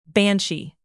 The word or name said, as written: banshee